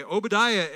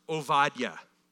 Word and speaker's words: Obadiah: we would call him Obadiah. Okay, Obadiah is actually pronounced Ovadiah.